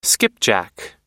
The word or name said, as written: skipjack